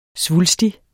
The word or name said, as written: svulstig